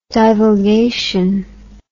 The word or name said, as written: divulgation